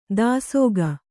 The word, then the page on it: ದಾಸೋಗ: dāsōga